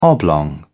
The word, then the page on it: oblong: British English